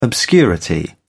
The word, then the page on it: obscurity: British English